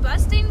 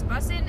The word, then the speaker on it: bussin': So he always said busting because it's like a joke because he said it wrong. So I always thought it was busting because he would always say busting, but apparently it's bussin' and like, yeah.